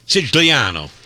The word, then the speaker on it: Sicilia: Siciliano.